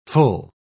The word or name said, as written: full